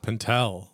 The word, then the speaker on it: Pentel: Pentel, like emphasis on the L.